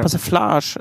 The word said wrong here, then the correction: Persiflage: Ja, äh, Persiflage. Persiflage, genau.